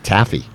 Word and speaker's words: Taffy: Wait, did you say her name was Taffy? – Taffy. T-A-F-F-Y.